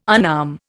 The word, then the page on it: unarm: Indian